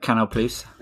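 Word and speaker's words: Canal+: Yeah, and then separately, I have been asked to do an interview with Canal Plus. But how do you say that? – Canal Plus.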